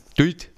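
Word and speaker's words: Dult: So wird es aber überhaupt nicht ausgesprochen, wenn man in Passau zu jemandem geht und sagt, sag mal, gehst du dann mal auf die Duld? Dann heißt es, was meinst du, die Duld? Ah, Duld. Duld heißt es.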